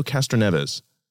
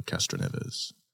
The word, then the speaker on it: Castroneves: She held her own, and with only three laps to go, Danica passed up Brazilian Elio Castroneves to take the lead. – Oh, beautifully pronounced. – Ooh. – I would have said Helio. – Yeah, Elio Castroneves.